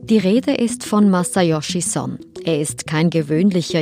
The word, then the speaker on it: Masayoshi: Ähm, sein Vorname wird korrekt Masayoshi ausgesprochen, aber er lässt sich häufig Massa nennen weil das auch Amerikaner, Ausländer allgemein besser aussprechen können.